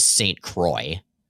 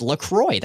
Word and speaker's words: LaCroix: No, then it would be Louis St. Croix. – What? – That's literally how you pronounce it. It's LaCroix. That's the official pronunciation. I looked it up.